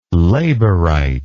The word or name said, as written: labourite